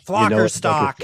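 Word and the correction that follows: Flickerstick: It's Flicker Stick.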